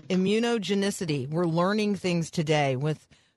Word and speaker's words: immunogenicity: So immunogenicity basically is sort of the... – Wait, slow down. Teach me how to say it. – Immunogenicity.